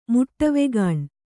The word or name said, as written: ಮುಟ್ಟವೆಗಾಣ್